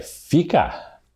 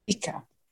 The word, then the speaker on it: fika: But okay, Fika, got it. – Fika.